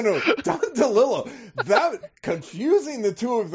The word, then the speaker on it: DeLillo: No, it's Don. Yeah, it's Don DeLillo. It's not.